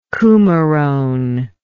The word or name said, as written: cumarone